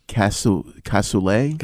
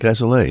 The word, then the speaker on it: cassoulet: Cassoulet? – Cassoulet, yeah.